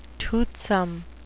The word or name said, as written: toothsome